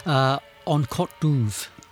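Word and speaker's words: An Cat Dubh: You're going to have to help me with the pronunciation of this. In English, it's called The Black Cat. – En Côte d'Ouvre. – Yes, there it is.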